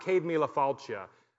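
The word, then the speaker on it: Céad míle fáilte: And this plaque, it's pronounced Cade Mila Falcia, but it means 100,000 welcomes.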